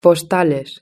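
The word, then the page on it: postales: European Spanish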